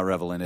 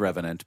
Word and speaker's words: Revenant: Yes, I pronounced it wrong. It's not Revenant. It's Revenant. But yeah, still.